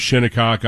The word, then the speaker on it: Shinnecock: Open on a Father's Day in the final round, and you're looking at a course that is as celebrated as Shinnecock out on Long Island, is, and that's how you pronounce it, by the way, because I worked in New York, Long Island.